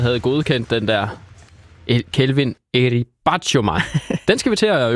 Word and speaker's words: Batshuayi: Så skal vi sidde på til Horsens Kelvin, og nu undskylder jeg på forhånd, Eti Batshuayi bragte AC Horsens foran i første halvleg.